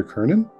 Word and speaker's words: Kerney: I'm Perry Clark, licensed marriage and family therapist, here with Viktor Kerney.